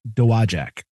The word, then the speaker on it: Dowagiac: It's pronounced Dowagiac. – Dowagiac. Okay. – Three syllables. – Yeah, that G-I-A-C at the end there is the tough part.